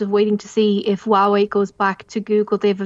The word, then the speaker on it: Huawei: Huawei P50 will have Google installed.